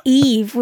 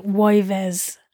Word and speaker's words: Yves: No, you wouldn't say Eve, would you? If you were going to say it like that, whatever. You'd say like Yves.